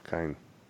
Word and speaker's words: Cian: Kine. If it's Irish, that's a hard C usually. – Yeah, Kine.